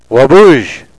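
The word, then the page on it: waabooz: Nishnaabemwin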